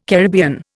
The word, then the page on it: Caribbean: Indian